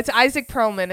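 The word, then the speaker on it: Itzhak Perlman: And this very famous violinist, I can't really pronounce his name, so I just know you're fabulous. It's Isaac Perlman. It's not that hard.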